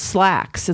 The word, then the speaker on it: Slaxx: We did, and that buddy watch I chose actually, I think, and it's called Slacks. It's a 2020 movie, and it's spelled S L A X X, and it's a reference to slacks, like pants, like wearing pants.